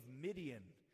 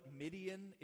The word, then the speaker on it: Midian: Midian was a nation that warred against God's people, and Midian brought about Gideon, and that's kind of cool because it rhymes.